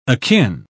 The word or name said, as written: akin